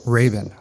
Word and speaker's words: Rabin: Rabin. – Oh, I've been saying it wrong. – Oh, it's okay. Everybody does. It's the Americanized version of Rabin. – Okay. – It's like Robin with a hard A.